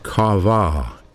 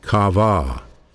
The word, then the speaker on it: kavah: It's a word pronounced kavah, kavah.